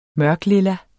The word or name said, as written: mørkelilla